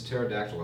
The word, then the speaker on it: pterodactyl: Why is pterodactyl have a silent P at the beginning? – Is that the answer? Greek.